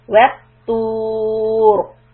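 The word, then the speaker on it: الطور: So when we stop on ra, the letter should be pronounced heavy. وَتُور. Repeat. وَالطُّور وَالطُّور.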